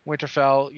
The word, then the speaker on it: Winterfell: Winterfell, yes, you pronounced it correctly last time.